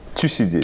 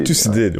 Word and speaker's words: Thucydide: je ne sais pas mal prononcer son nom, c'est quoi, Thucydide? – Thucydide. – Thucydide, ouais.